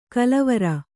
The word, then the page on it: ಕಲವರ: kalavara